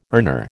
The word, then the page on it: earner: American English